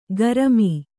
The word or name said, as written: ಕೃಮಿ